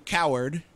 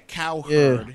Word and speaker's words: Cowherd: Would you call him Coward? No. – Wait, his last name's actually Cowherd? – Yeah, Cowherd.